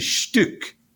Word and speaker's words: Stück: It's actually Stück. In German, it's the U with an umlaut. It's Stück, which is a piece. Yeah, a piece of something. And then in Yiddish, it's pronounced Stück. So it means a piece, but it's just like in English, we would say a bit, like a comedic bit.